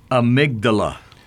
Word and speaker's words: amygdala: You know how to pronounce it? – I do. – Go for it. – I looked it up. – Okay. – Amygdala.